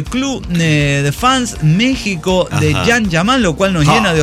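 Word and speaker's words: Can Yaman: Con el can, que no es el perro, sino el Jan Yaman, ¿no? Ah, aprendimos el nombre. Se pronuncia Jan Yaman.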